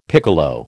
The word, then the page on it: piccolo: American English